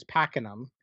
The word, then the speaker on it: Pakenham: Does it rhyme with bacon and ham? – Well, close. It does rhyme with the last. My last name is Pakenham, but some Oh. – people call it Pakenham.